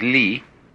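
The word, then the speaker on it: Li (理): Ri is a Chinese character, pronounced by the Chinese Li,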